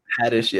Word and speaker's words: Haddish: Was it Haddish or Haddish? Or how do you say it? – Haddish, yeah, Haddish.